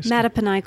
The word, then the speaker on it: Mattaponi: So, you're the author of The Gulf, a novel, um, and The Art of Waiting, and uh, I can't pronounce this, Mattaponi Queen? – Mattaponi Queen. There you go.